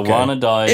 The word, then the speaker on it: Wannadies: And a patron Jay Wilson has given us a pronunciation answer on how to pronounce the Wanna Dies. It's the Wanna Dies.